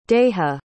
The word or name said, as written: daija